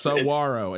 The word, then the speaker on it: Saguaro: I, am still, I, I, believe I'm saying it wrong. I'm, I'm, I'm working on it. Saguaro. – Yeah, so, no, it's, Saguaro. Is it's that give give it to me yeah, one time? Saguaro. Coach. There you go. – Saguaro. – I'm gonna have to say it like that. Saguaro. Okay. There is a there is a Saguaro the way you're saying it.